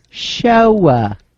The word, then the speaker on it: Shoah: Shoah. Pronounced Shoah.